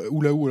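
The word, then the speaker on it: Hula Hoo: Ok, bah tiens, autre petit prix, euh, Oulaou, euh, un jeu de cartes, Oulaou, alors comment ça s'écrit H-U-L-A espace H-O-O.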